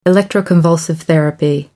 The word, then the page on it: electroconvulsive therapy: American English